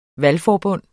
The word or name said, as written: valgforbund